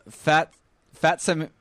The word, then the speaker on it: Phatsemelia: Band called Fatsimilia.